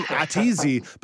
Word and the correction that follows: A to Z: but now that I think about it, is it A to Z? Tell me about A to Z, Ateezy, your company, and your overall goal and your mission. – It is A to Z, yes. You are not the first person to mispronounce or butcher that name, but yes, it is an accessible travel website.